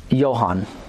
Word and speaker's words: Johann: Last name is spelled T-E-T-Z-E-L. – John or Johann? – Johann. J-O-H-A-N-N.